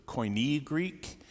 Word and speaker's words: Koine: When I was talking about the ancient religions, I was talking about Greek, and it was the Koine Greek, and I added an extra couple of syllables there. I said Koinonia Greek.